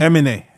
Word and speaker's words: Aminé: How do you pronounce his name? Aminé? Aminé. Aminé? – Aminé.